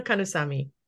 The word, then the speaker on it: Kannusamy: No, it's Shobna Kannusamy.